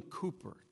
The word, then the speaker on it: Cowper: I remembered that the song was composed by, at least the words were composed by William Cooper. It's C-O-W-P-E-R.